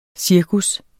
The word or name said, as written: cirkus